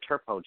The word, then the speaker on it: Turpeau: I'll just spell it for them right quick. It's T as in Tom, U-R, P as in Peter, E-A-U, the Turpo Channel on YouTube.